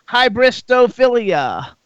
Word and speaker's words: hybristophilia: Let's first talk about hybristophilia. Yes, hybristophilia. Is that how I pronounce it? Yes, you said it correctly, and it's with one of your friends.